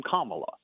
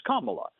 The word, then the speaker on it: Kamala: She pronounces her name Kamala. It doesn't matter how the rest of us would pronounce it or how we think it's pronounced. Mm-hmm. She says it's Kamala.